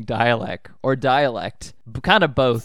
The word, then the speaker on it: Dälek: But yeah, this is how I knew they were pronounced dialect.